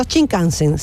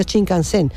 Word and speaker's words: Shinkansen: o sea, el acento en el kan, no Shinkansen, sino Shinkansen.